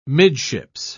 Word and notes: midships: British English

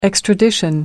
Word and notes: extradition: British English

